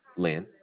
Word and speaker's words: Lin: D-O-N. – Don. What's your last name? – Lynn. – How do you spell it? – L-I-N.